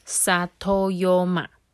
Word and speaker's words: 里山: 他说“ 里山” 这个词原本是来自于日文呐，发音叫做 “satoyoma”。我希望我没讲错。